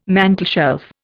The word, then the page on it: mantelshelf: Indian